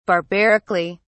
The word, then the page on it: barbarically: British English